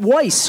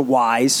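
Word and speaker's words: Weis: I called it Weiss because I didn't even, you know, like I didn't even know how to pronounce it.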